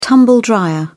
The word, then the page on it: tumble-dryer: British English